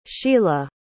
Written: Shiela

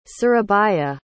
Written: surabaya

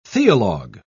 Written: theologue

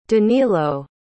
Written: danylo

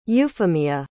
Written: euphimia

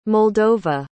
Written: moldova

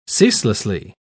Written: ceaselessly

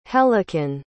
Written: hellekin

